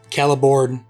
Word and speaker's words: Celeborn: Also, in the book, unlike this movie, C sounds in Elvish are hard C. So Cirdan, Celeborn, Cirith Ungol.